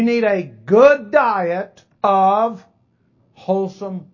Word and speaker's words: Holsum: Matter of fact, I imagine most of us recall a bread company called Wholesome. H-O-L-S-U-M. They didn't spell it the same. But the pronunciation and the sound mimic the W-H-O-L-E-S-O-M-E. Wholesome.